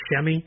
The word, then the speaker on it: Semi: They drafted Semi Ojeleye, or Ojeleye, however that gets said. We'll learn that, and I guess it's Shemi, according to the official NBA pronunciation guide.